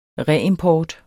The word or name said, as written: reimport